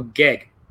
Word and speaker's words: GEG: Global GEG. Rhymes with egg.